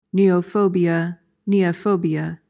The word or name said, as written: neophobia